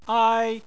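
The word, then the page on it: ai: tone 1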